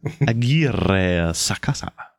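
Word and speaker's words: Aguirre-Sacasa: And we were too lazy to go on Wikipedia and look up Adiri Sakasa. – Fine. Be that way. – Roll your R's more. Roll your R's more. – Aguirre Sakasa. – There you go.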